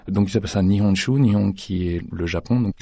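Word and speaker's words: Nihon (日本): On dit Nihon. – Nihon. N-I-H-O-N, ça s'écrit, c'est deux mots, Nihon.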